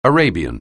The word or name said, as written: Arabian